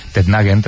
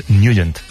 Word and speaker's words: Nugent: Hombre, siempre lo hemos llamado en el barrio Tednagen o Tednagen. – Claro, yo era de Tednagen. – Pero la pronunciación correcta es Nugent.